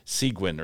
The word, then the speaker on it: Seguin: Now, Seguin's a funny place because, first of all, if you see the word in print, you have no concept of how to pronounce it. It's just S-E-G-U-I-N, Seguin or something like that. – Yeah, Seguin. – It's Seguin, named after Juan Seguin, who was a Tejano patriot.